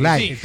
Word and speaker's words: Live: La dije bien, Live. Se escribe Taquilla Live. – Live con sí, V. – Pero en Taquilla Live se pronuncia así.